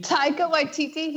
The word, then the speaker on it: Taika Waititi: Is it like Taika Waititi?